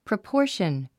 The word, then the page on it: proportion: prəpɔ́ː r ʃən; プロポーション